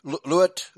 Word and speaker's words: Luet: Although the first date cannot be accurately pinpointed, the seer himself described it to Mr. Lud Kirsten. Luet. Okay, it says two syllables.